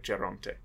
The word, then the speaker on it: Geronte: We have Marcello Alvarez as Desgrieux, Christopher Maltman as Lescaut, Brindley Sherritt as Geronte, or I guess the Italian pronunciation, sorry, Geronte.